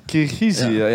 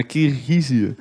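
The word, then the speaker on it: Kirgizië: Kyrgyzije. – Ja, ik heb Kyrgyzstan opgeschreven. Ik denk, die ga ik goed uitspreken. Ja. Maar Kyrgyzije. – Ja, Kyrgyzije.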